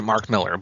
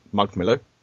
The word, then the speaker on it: Mark Millar: Uh, he, well, he pronounces it Mark Miller, but yes, it's spelled Mark well, Millar. Actually, he pronounces it Mark Miller.